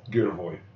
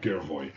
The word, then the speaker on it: Girevoy: Gervoy. I'm pronouncing it right. G-I-R-E-V-O-Y, Gervoy.